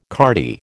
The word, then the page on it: cardy: American English